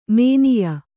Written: ಮಾನಾಯ

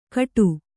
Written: ಕಟು

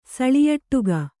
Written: ಸಳಿಯಟ್ಟುಗ

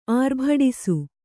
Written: ಆರ್ಭಡಿಸು

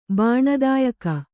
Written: ಬಾಣದಾಯಕ

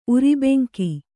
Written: ಉರಿಬೆಂಕಿ

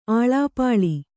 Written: ಅಳಿಪಳಿ

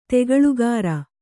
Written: ತಗಲುಗಾರ

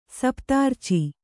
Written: ಸಪ್ತಾರ್ಚಿ

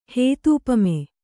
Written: ಹೇತೂಪಮೆ

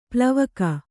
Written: ಪಾಲ್ವಕ್ಕಿ

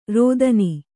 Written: ರೋದನಿ

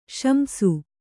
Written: ಶಂಸು

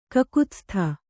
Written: ಕಕುತ್ಸ್ಥ

